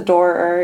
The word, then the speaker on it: Doerr: It's like the D-O-E right before the R. – It's just the Doerr, and you just blend it, and it's fine.